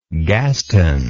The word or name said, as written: Gaston